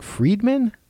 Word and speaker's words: Fridman: Another good podcast I'll recommend people listen to this week is the Lex Fridman podcast, and it's spelled F-R-I-D-M-A-N.